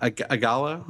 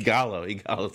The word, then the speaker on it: Ighalo: And then Ighalo. Am I saying that right? Of course not. – Ighalo, I think. – Ighalo, thank you.